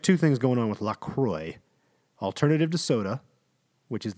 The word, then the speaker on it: LaCroix: How is LaCroix pronounced? LaCroix. It rhymes with enjoy. Remember that.